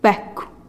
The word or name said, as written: bekuh